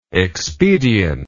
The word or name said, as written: expediently